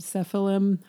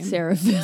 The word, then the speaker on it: Seraphim: But the more loved Cephalim. How do you pronounce their name? – Seraphim.